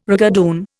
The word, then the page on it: rigadoon: Indian